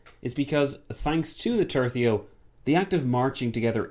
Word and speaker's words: tercio: My pronunciation of this word has gone from tercio to tercio to tercio to tertio, so it's going all over the place. But now we've landed on tercio, which I'm told is correct.